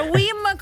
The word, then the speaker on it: Wim: Si pronuncia Wim come Wimbledon senza Bledon.